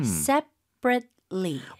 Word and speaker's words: separately: 영어에서는 으 하는 소리가 나오면 안 됩니다. So, separately. 이에다가 처음 이에다 강세를 좀 줘야 될 것 같고요.